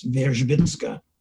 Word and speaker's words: Wierzbicka: Um, Anna Wierzbicka, the um, anthropological linguist at Australian National University, um, if you see her name, you won't pronounce it properly because it's W I E R Z B I C K A, but it's Verzhbinska.